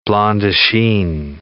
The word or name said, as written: blanc de chine